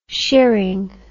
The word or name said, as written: shearing